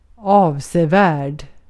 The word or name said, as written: avsevärd